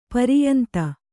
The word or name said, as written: ಪರ್ಯಂತ